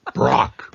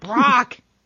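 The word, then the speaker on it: B'rock: I'm sorry, B apostrophe R-O-C-K, which is pronounced Brock. – Brock.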